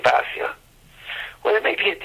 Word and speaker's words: Basya: Basya, a common name today, should be Bisya, as the daughter of Paro is called in Divrei HaYamin.